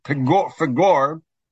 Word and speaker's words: Peor: Peor, or probably more properly pronounced, Pegor, uh, is the name of the mountaintop that Bilam went to, one of the mountains of, of Moab.